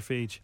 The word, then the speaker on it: Feige: Is it Feige or Feige? – It's Feige. It's like that yogurt, no one else to pronounce it.